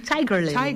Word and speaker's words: Tiger Lily: Tiger Lily? – Nee, Tiger Lily. – Tiger Lily. De klemtoon.